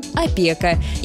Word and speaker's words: опека: Опекать, опекун, опека. И в итоге правило простое. Правильно говорить и писать «опека» без буквы «ё».